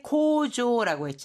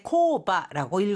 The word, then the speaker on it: 工場: 자, 공장이라고 할때 코조라고 했잖아요. 이건 또 다른 읽는 방법이 있는데 코바라고 읽을 수도 있습니다. 자, 코조라고 읽을지 코바라고 읽을지 코바로 읽었을 때는 규모가 아주 작은 공장이 돼요. 그러니까 간의 수공업이라든가 아주 작은 규모일 때는 코바라고 읽고요. 일반적인 어느 정도의 규모를 갖춘 공장을 말할 때는 코조와 같이 읽어줍니다.